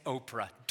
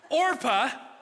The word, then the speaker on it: Orpah: Don't say Oprah. I dodged it once. Luckily, she's gonna exit stage right after chapter 1 is over, so I don't have to say her name after today. Orpah. Everybody say Orpah.